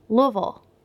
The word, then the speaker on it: Louisville: Louisville. – Yeah, better. One more time. – You're supposed to say it like you have marbles stuck in your mouth. – And it rhymes with shovel.